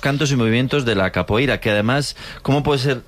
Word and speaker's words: capoeira: Pues todos sabemos lo que es la copeira, ¿verdad? – Capoeira. – Qué mal pronuncio últimamente. – Capoeira.